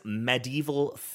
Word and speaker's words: medieval: We say medieval. It is not mid. It's med. No, that's correct.